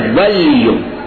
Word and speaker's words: ولیو: ولیو واو پہ زبر ہے لام ساکن ہے